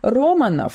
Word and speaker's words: Романов: Романов с ударением на первый слог оставил глубокий след в ночной жизни Лос-Анджелеса.